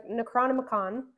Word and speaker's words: Necronomicon: There, they find an old book, the, Necronom- the Necronomicon. it's like, man, you better say that right.